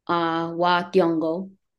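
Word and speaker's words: wa Thiong'o: He's Kenyan, but I believe it's pronounced Wakyongo.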